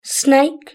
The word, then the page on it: snake: British English